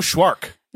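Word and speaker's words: Szwarc: Jeannot Schwark. – Jeannot Schwark? – We should know. – His name rhymes with shark.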